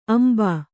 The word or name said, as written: ಅಂಬು